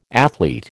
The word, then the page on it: athlete: American English